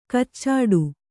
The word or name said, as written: ಕಚ್ಚಡ